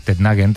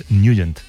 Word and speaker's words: Nugent: Hombre, siempre lo hemos llamado en el barrio Tednagen o Tednagen. – Claro, yo era de Tednagen. – Pero la pronunciación correcta es Nugent. – Te fuiste a Washington y descubriste que era Nugent.